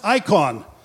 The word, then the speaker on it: eikon: Again, that word image, it's a Greek word, E I K O N. It's pronounced icon.